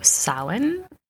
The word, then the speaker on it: Samhain: So Samhain, that is how you pronounce it. It's kind of spelled like Samhain, and that is Halloween, October 31st.